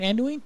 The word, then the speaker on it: Anduin: Anduin.